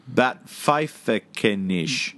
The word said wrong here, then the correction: Backpfeifengesicht: No, backpfeifengesicht. – Backpfeifengesicht. – That's it.